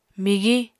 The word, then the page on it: migiñ: Apatani